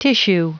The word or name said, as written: tissue